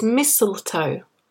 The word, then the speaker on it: mistletoe: It's mistletoe. mistletoe. So the first T is not pronounced.